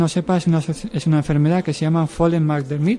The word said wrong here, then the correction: Phelan-McDermid: el síndrome de Fela McDermid.